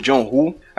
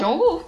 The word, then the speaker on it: John Woo: Eu falei Woo? Foi. Tu falou Woo. Ou não? – E como é que fala? Woo. E como é que eu disse? Woo. Como é que fala? John Woo, que nem Michael Jackson.